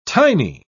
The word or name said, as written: tiny